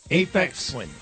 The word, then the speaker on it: Aphex Twin: How do you say it? – Aphex Twin. – Okay. – With an A.